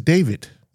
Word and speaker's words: David: Keith Davis. – It's not Keith Davis. – It's David. – You said Davis.